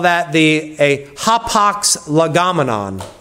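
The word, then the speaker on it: hapax (ἅπαξ): That once preposition in Greek is actually hapax. That's how you pronounce it in Greek.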